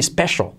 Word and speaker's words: special: It's really special. All right. En ese sentido, con esa C, se hace como una SH, special. Y luego la L al final de palabra, special, que casi no se pronuncia.